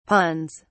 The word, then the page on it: puns: British English